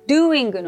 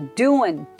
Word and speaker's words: doing: ポイントは doing の G を発音しないで doing, doing, doing ということです。